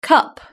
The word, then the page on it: cup: British English